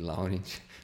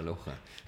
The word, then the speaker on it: Laurent: Aí os médicos de esportes falavam para ele, Lohan, o nome dele. E se escreve Laurent, e a gente chamava ele de Laurent. Aí ficava bravo, é Lohan.